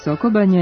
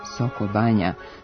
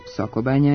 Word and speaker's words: Sokobanja: Soko banja je ime za naseljeno mesto i banju i piše se kao jedna reč sa kratkouzlaznim akcentom na prvom slogu. Greška u pisanju nastaje najverovatnije zbog toga što se u govoru često čuje Soko banja s dva kratkosilazna akcenta, što vodi i ka dve reči u tekstu. No, prema pravopisu, Soko banja je jedna reč s jednim akcentom i jednim velikim slovom.